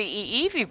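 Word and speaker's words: Iiwi (ʻiʻiwi): That's the Iiwi bird. – The Iiwi bird.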